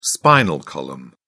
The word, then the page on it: spinal column: British English